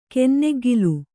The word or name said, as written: ಕನ್ನೆಗ್ಗಿಲು